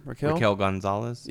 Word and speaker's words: Raquel Gonzalez: Raquel Gonzalez.